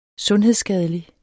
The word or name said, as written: sundhedsskadelig